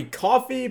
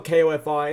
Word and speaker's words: Ko-fi: Well, it might be coffee, but it's spelled K-O-F-I, and that's weird, so I say it Ko-fi.